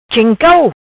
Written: Cheng Cau

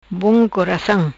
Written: Coracam Bom

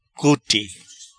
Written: Cute

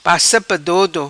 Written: Passa pa dodo

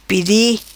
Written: Pedi

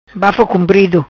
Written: Bafo cumprido

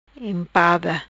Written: Impada

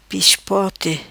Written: Pispote

